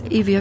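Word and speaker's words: Evia (Εύβοια): Mit diesen Worten des Songs Kalokeri, Sommer, des Musikers Dionysios Savopoulos im Ohr, erreiche ich die zweitgrößte griechische Insel Euböa, im Griechischen einfacher Evia ausgesprochen.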